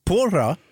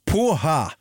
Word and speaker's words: porra: Han säger också även någonting på portugisiska som man uttalar fel. Han säger porra. Porra uttalas poha och betyder fritt översatt sperma ungefär.